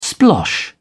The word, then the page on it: splosh: British English